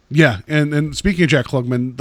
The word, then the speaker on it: Klugman: It's Klugman, yeah.